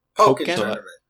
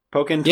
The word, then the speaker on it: Pokken: Pokken. – Pokken. – Yeah. They have announced locations for regionals, nationals, and also prize support, which they have over $100,000 in prizes going for Pokken.